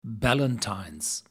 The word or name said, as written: Ballantine's